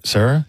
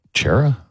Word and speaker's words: Cera: Sarah? – He's how else would you pronounce it? – Chera? I don't know. – Oh. – Well, C-E-R-A. – It's just Michael Sarah.